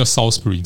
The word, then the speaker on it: Salisbury: 对，它就变成 Salisbury，所以你在当地念 Salisbury，你一定会被 100%、100万会被当成是外地人。 – 我相信我出现，然后讲个两句话，应该就是很像外地人。 – Salisbury 哈，我们这边更正一下，就是发音叫 Salisbury 这样子。